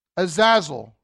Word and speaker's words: Azazel: But in my limited research of what I found, this word scapegoat is the idea, let me spell it for you A Z A Z E L. Azazel, I guess is how you would say it. A Z A Z E L. Pastor Steve, am I saying that right?